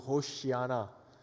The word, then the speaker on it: Hoshiana: Okay, if you wanted to say it like a Hebrew, Hoshiana. Hoshiana. Hoshiana for short.